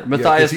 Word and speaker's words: Matthias: Matthias became the 12th.